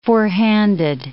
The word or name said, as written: four-handed